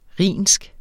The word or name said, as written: rhinsk